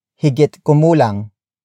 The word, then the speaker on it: higit kumulang: Word for word, it's higit kumulang sampu.